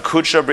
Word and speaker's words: kudsha: Kudsha, it's like HaKadosh Baruch Hu, but in Aramaic it's kudsha, so I would spell it like K-U-D or Kuvav Dalet Shin Aleph, kudsha, and then brichu, yeah.